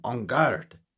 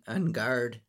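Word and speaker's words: Enguarde: And Ongard, or however you pronounce it, I guess it's that French thing. Ongard, like what you say when you're going to have a sword fight.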